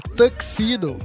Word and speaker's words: tuxedo: Especial, ó, aprendi como é que fala. É tuxedo, não é tuxedo. Alô, rapaziada aí que fala tuxedo, igual eu sempre falo. Não é tuxedo, é tuxedo. Pegou a visão? Tuxedo. É isso.